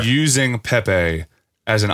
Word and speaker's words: Pepe: Is it pronounced PayPay or Pepe. – Pepe. – That's right.